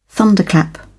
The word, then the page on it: thunderclap: British English